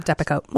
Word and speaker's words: Depakote: It's a drug called Depakote, am I saying that right? – It is. Yep, yeah, Depakote.